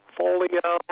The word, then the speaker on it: Folio: It's like portfolio without the port. F-O-L-I-O.